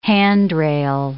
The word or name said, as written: handrail